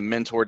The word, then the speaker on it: Mentor: the mentor defensive tackle. It's mentor, right? Not mentor.